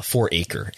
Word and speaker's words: 4 Acre: And again, that agency in this case, according to Front Office Sports, is this Four Acre is how you say that. 4 Acre. – Oh, I said Farachi like it was some crazy Italian dish.